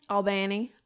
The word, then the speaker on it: Albany: How do you say it? – Albany. – Oh, Albany. – That's how everyone says it. Albany, Georgia.